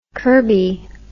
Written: curbie

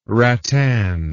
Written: ratan

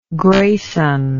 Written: grayson

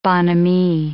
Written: bonhomie

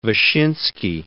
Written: Vyshinsky

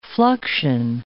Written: fluxion

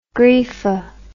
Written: greefa

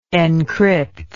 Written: encrypted

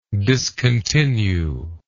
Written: discontinuer

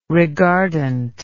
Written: reguardant

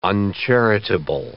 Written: uncharitable

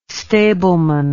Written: stableman

